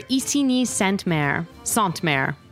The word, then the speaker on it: Isigny Sainte-Mère: My guest today is Kara Warren, the East Coast Sales Manager for Isigny Sainte-Mère. Kara, did I say it right? – You did pretty good. – Okay, I did okay. Yeah. I'll give it a B.